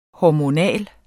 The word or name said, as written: hormonal